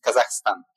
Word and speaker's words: Kazakhstan: And I know that in Italy, everybody told Kazakhstan. Yeah, so that the right pronunciation is Kazakhstan to make it everything crystal clear.